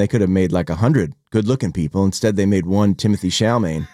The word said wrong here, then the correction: Timothée Chalamet: Timothée Chalamet. – Oh, Timothée.